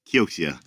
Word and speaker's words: Kioxia: Is it Kioxia? Kioxia? How do you guys pronounce it? – Kioxia. I usually say like an old Yakuza gangster. Kioxia. – Kioxia.